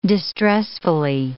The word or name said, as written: distressfully